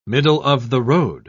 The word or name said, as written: middle-of-the-road